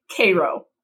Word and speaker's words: Cairo: That is how we pronounce that city, Cairo.